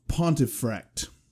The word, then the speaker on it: Pontefract: We're talking about the Black Monk of Pontefract.